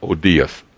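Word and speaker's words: oudeis: That word nothing is a combination of two Greek words, and it's pronounced odias, O-U-D-I, O-U-D-E-I-S.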